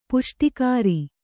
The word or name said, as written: ಪುಷ್ಟಿಕರ